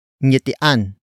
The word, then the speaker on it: ngiti: Word for word it's ngitian ang sanggol.